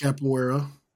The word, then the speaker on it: Capoeira: Capoeira.